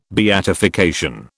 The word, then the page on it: beatification: British English